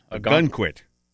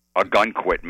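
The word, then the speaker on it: Ogunquit: A gun quit. – A gun quit, Maine.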